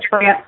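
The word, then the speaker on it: Frantz: Yes, it's Jessica France, and the last name is spelled F-R-A-N-T as in turtle, Z as in zebra. So it's pronounced like the country, just spelled out.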